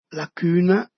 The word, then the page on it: la cüna: Dialetto di Albosaggia